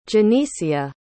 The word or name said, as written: genisia